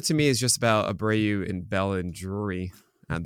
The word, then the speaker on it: Abreu: The other options here, Jose Abreu, I'm pronouncing him right.